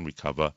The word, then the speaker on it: Recuva: So there's one called, there's a free software as well called Recover, but it's spelled R-E-C-U-V-A. And